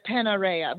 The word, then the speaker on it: Panarea: Hosting dinner parties in the teeny Sicilian island of Panaria. Am I saying that right? How do you pronounce that? – We can go with Panarea, but I'm sure they've heard worse and will accept all.